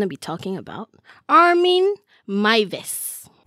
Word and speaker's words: Meiwes: Armin, A-R-M-I-N. – Okay. – Then Mives, M-E-I-W-E-S, because he's German. – Oh. – So it's pronounced as Mives.